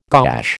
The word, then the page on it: gouache: American English